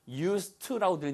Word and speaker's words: used to: 발음이 used, 드가 아니고 used to라고 들린다는 거 알아두셔야 돼요.